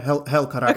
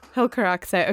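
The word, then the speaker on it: Helcaraxë: Did I say that right? – More or less, yeah. Helcaraxe, okay. – Helcaraxe. Okay, great.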